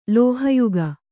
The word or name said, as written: ಲೋಹಯುಗ